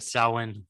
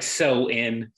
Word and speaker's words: Samhain: That's how you say it, right? Samhain. – Yeah, it's like so in.